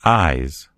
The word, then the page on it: eyes: American English